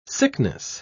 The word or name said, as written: sickness